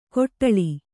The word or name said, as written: ಕೊಟ್ಟಳಿ